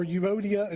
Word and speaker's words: Euodia: And according to the Bible pronunciation guide website that I looked this up on, their names were Euodia and Syntyche, because I had no idea how to say either one of those.